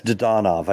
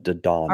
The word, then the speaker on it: Dadonov: Yeah, it's Dadonov. I called yesterday to confirm because it's been pronounced every which way, but Dadonov.